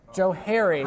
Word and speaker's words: Johari: Joe Harry. – Yeah, there you go. I was mispronouncing it. I thought you meant the Johari. You're right, it's Joe Harry. Joe and Harry.